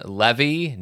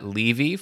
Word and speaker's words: Levy: But before I get into that, just want to point out that I do believe it is pronounced Sean Levy, not Levy, for the director of Deadpool 3.